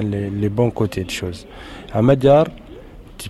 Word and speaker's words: Amadjar: Et ce disque donc qui sort, qui s'appelle Amadjar, comment on en prononce ? – Oui, Amadjar.